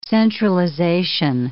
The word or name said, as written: centralization